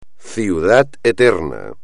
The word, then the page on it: Ciudad Eterna: espagnol